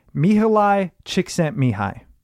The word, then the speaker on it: Mihaly Csikszentmihalyi: So I actually think I know how to pronounce it because I've referenced this a few times and it's Mihaly Csikszentmihalyi.